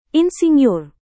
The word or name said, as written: insinyur